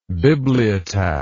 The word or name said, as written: bibliotaph